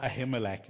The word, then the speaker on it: Ahimelech: So he goes to a priest called Ahimelech. It's almost like Abimelech, but with a H. okay. That's what makes it difficult to pronounce. Now, David can't go to Ahimelech and reveal to him that, listen, I'm on the run from David or from Saul.